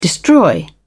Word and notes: destroy: British English

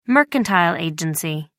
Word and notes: mercantile agency: American English

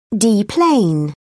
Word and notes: deplane: British English

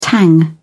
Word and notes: tang: British English

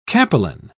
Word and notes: capelin: British English

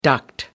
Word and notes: duct: American English

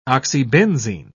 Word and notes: oxybenzene: British English

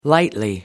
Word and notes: lightly: American English

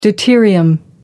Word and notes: deuterium: American English

